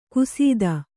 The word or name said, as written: ಖಸ್ಥ